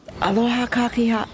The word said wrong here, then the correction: aloha kakahiaka: Gotta pronounce it correctly. Aloha kakahiaka, that's good morning in Hawaiian.